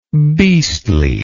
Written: beastliness

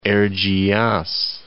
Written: Erciyas

